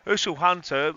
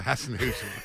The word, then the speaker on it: Hasenhüttl: Southampton defended for their lives, they really did, and Usul Hunter was, was um, he, he was so pleased. – Yeah, Uzil Hunter.